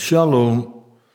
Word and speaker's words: shalom: Nee, zo moest dat van onze gids. Dus, zo spreek je dat uit. Shalom. En dit shalom.